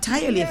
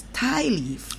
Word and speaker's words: tie leaf: I use that in my mind as understanding, say tire leaf, but it's not tire leaf, it's tie leaf. – Oh, tie leaf, that's in T I E when you tie.